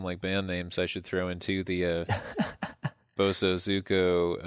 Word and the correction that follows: Bosozoku: Man, am I saying that right? Let me just check it real quick. Bozo Zoku.